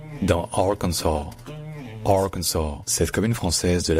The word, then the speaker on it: Arkansas: Dans Kansas, en fait, l'accent tonique est sur le can, donc c'est Kansas. Alors que dans Arkansas, l'accent tonique est sur le A, donc c'est Arkansas, Arkansas. Et donc le KN dans l'Arkansas, il n'est pas du tout accentué. C'est juste un KN. En fait, on n'entend presque pas la voyelle.